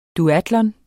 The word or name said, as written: duatlon